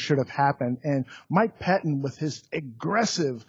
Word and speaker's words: Pettine: Well, first and foremost, I think the biggest concern for the Chicago Bears is the defensive coordinator, Mike Pettine. Did I pronounce that correctly? – You did, yes.